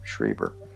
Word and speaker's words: Schreiber: How's it pronounced? Schreiber.